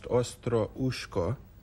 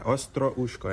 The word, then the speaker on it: Ostrushko: In Ukrainian, it's pronounced Ostroushko. – Ostroushko. – Yeah, you have to be able to roll your R's in the Ukrainian language. – Re. – Yeah, Ostroushko.